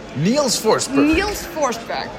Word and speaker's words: Niels Forsberg: Niels Forsberg. – Is the way people from English-speaking countries have a tendency to pronounce it, I guess.